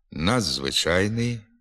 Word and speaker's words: надзвичайний: Приголосний «д» перед свистячими «з», «ц», «ц» змінюється у вимові на звук «дз». Пишемо «відзначати», «надзвичайний», «підземний», а вимовляти треба «надзвичайний», «підземний», «відзначати».